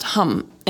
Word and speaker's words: ham: But today we're going to sing the mantra So Hum, and it's spelled So as in S-O, but then the word hum is spelled H-A-M, but it's pronounced hum.